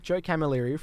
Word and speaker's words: Camilleri: We had, how do you say his name? – Joe Camilleri from the Black Sorrows. – Yeah. – Oh, no. – And I called him Joe Calamari live on air.